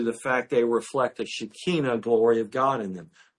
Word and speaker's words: Shekinah: The reason why I say Shekinah is that's the proper Hebrew way to pronounce that word. You can say Shekinah, but really it's pronounced when you talk to an Israeli. They say Shekinah.